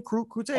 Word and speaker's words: Crute: Crute? – Hey, I'm pretty sure. Yeah, Crute.